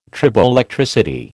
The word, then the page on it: triboelectricity: American English